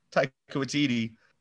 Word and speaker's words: Taika Waititi: Well, Korg himself, Taika Waititi, is that how it's pronounced? That's how I've been pronouncing it for years.